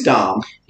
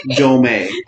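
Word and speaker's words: Domme: It's Dom. – It's Dom. – Or Dame. Some people say Dame. – Yeah. I say Dame. – Yeah. – Yeah. – What is not, let me reiterate, is not Dome.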